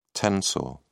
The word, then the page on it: tensor: British English